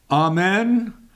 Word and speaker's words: Amen: It's A-M-N, but we pronounce it Amen.